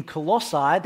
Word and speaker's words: Colossae: Because what Paul is doing here, the whole way through this passage, is he's helping these new Christians in Colossae, that's roughly how you say it if you want, and he's asking them to get their heads around who it is that they've come to believe in.